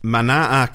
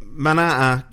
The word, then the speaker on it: Manaa: Now, Manaa Katanga roughly translates. – Because Maori doesn't have apostrophes in it. – Well, there's no. It's M-A-N-A-A. Because you told me you have to pronounce every vowel.